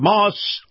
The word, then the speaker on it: Maus: Moss Acura. Now, Moss is spelled M-A-U-S.